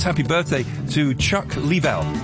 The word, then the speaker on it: Leavell: Born 1952, Chuck Leavell. Leavell, get his name right.